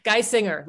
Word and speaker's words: Geissinger: Geissinger, like a male vocalist. Geissinger.